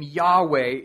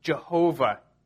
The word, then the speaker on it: Yahweh: The reason is it's because the Hebrew name Yahweh is being used. Sometimes that's pronounced Jehovah.